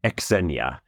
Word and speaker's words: Xenia: I think it's pronounced Xenia? Xenia. – Almost like a KS at the front of that. – Okay. Xenia.